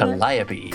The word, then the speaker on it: Calliope: Is that who's saying Calliope.